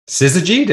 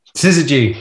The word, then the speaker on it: Syzygy: But Syzygy, did I get that right? – You got it right. – Yes. Chris is going to be so happy. – Nice. Okay. Syzygy.